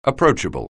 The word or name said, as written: approachable